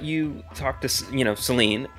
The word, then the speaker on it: Saline: Saline. – So close, bud. – Well, that's how it's spelled, isn't it? – It's Saline. It's pronounced Saline. – Oh, I put the wrong emphasis on the wrong syllable, you know? – Did you say syllable?